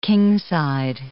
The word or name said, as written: kingside